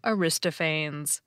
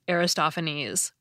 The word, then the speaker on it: Aristophanes: I called him Aristophanes. But it's actually pronounced Aristophanes, which I recognize immediately now that I hear it said the right way.